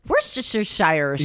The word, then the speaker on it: Worcestershire: Like, I used to be like, Worcestershire sauce. Yeah. – It's not pronounced like it's spelled or as it appears.